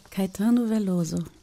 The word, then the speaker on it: Caetano Veloso: Caetano Veloso. – Caetano Veloso.